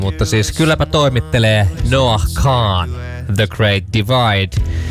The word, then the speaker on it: Kahan: Hyvä, Vomma to mulle opetti, kun mäkin oon aina sanonut Noah Kahan, ja sit Vomma oli vaan I don't want to be this guy, but it's Kahan.